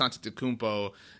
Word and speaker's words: Antetokounmpo: I'm thinking that me, Dante, and Giannis Antetokounmpo, that's a threesome that is going to be a lot of fun. – Hey, nice on the pronunciation, by the way.